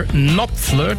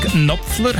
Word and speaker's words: Knopfler: Mr. Knopfler, das K vom Nachnamen wird eigentlich nicht ausgesprochen, aber wegen der Bindung mit dem Vornamen wirkt es häufig so bei Mark Knopfler.